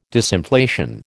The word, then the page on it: disinflation: American English